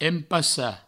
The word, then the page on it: empassar: Provençal; empassa